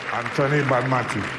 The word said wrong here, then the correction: Aitana Bonmatí: Aitana Bonmatí.